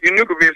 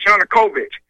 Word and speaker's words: Yanukovych: Yanukovych is not pronounced Yanukovych. It's Yanukovych.